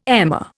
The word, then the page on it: emmer: Indian